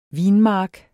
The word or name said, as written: vinmark